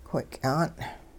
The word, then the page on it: whiky'an': Hupa